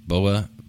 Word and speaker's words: boba: It comes with those, I always mispronounce them, boba. – Hopping boba. – Like Boba Fett.